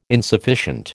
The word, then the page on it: insufficient: American English